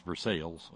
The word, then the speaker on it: Versailles: Many relatives from Greenville, Versailles, yes, it's pronounced Versailles, of course, just like in Kentucky, called it Pickway.